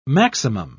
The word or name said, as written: maximum